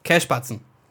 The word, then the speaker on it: Kässpatzen: Every time I've ever had someone home make, um, how how would you pronounce it again? Kässpatzen. – Yeah, that's good. – Boom. – You nailed it.